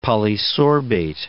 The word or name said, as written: polysorbate